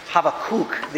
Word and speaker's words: Habakkuk: Habakkuk. The emphasis is on the last syllable, and one of the things I read was it's like saying have a cook.